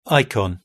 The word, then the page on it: icon: British English